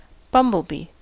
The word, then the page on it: bumblebee: American English